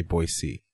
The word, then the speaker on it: Boise: How do you say Boise? – Boise. – People from Boise say Boise.